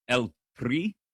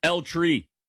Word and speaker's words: El Tri: I said that as white as possible. El Tri? It's not a place where you roll your R. El Tri, Mexico taking on South Korea tonight.